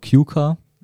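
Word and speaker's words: kyykkä: Die dritte, finnisches Gorotki, nennen wir auch Kyuka, ganz einfach ausgesprochen.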